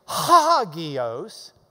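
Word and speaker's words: hagios (ἅγιος): But because it's open, it's hagios, H-A, not just A.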